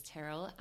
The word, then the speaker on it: Tyrrell: So yeah, we started a blog and we called it Squirrel Sisters, and that's because our surname is Tyrrell and Squirrel was our nickname at school. – Oh my gosh. – Because it rhymes with Tyrrell.